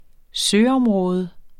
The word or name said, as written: søområde